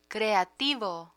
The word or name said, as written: creativo